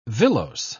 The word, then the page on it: villose: British English